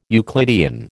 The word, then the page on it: Euclidean: American English